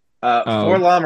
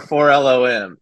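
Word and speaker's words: 4-LOM: For Lom or for L-O-M? – It's for L-O-M. It's not my choice. I did not do this to you. – The very diplomatic answer. – Yeah, it's like C-3PO, R2-D2. It's basically phonetic so that you can spell it correctly.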